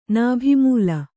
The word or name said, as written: ನಾಭಿಮೂಲ